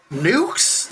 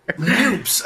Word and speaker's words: NEUPS: Nukes? Like, you say it nukes or noose? How do you say it? – Neither. – NEUPS. Oh, okay. All right.